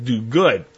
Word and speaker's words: Dogood: actually spelled D-O-G-O-O-D is one word, but pronounced Duguid.